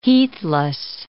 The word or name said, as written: heathless